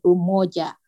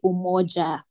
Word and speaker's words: umoja: So if you hear how Camilla said it, she said umoja. But in Kenya, we would say umoja, pronounce the J.